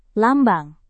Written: lambang

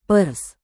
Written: pers

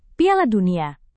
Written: piala dunia

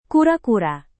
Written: kura-kura

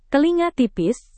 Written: telinga tipis

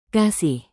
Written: kasih

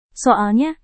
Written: soalnya

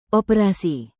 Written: operasi

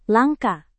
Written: langka

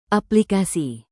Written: aplikasi

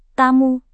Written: tamu

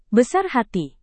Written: besar hati

